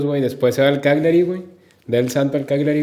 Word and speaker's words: Cagliari: Cagliari que dijiste Cagliari, iba a decir Caligari, güey.